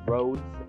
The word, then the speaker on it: Rhodes: That is made, Rhodes, I pronounce it like that, it's R-H-O-D-E-S.